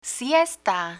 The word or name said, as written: siesta